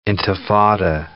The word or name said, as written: intifada